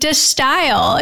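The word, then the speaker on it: De Stijl: And then we have, if I'm pronouncing this correctly, De Style. – I think it's Distill. – Okay. I looked up nerdily, looked up how to actually pronounce it. De Style is actually how you pronounce that.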